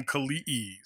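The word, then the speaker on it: Kali'i: And I'm Kalii Stone. – That's good. Everybody who's listening, you say his name Kalii. – Kalii. – Not Kalii.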